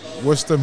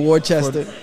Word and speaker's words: Worcester: Out of Worcester, Massachusetts. – Worcester. – Or Worcester. The non-locals. – Ah, excellent, yo. You guys are learning things. You guys are learning how to pronounce cities in Massachusetts.